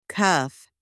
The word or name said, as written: cuff